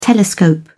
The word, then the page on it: telescope: British English